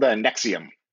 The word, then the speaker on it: NXIVM: They are called NXIVM. – How's it spelt? – It is spelled NXIVM, and those are all capital letters. So, you know, exactly like it doesn't sound.